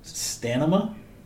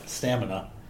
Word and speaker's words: stamina: Stanima? No, stamina. But you switched the M and the N? – Yeah, I heard somebody Ooh.